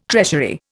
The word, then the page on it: treasury: Indian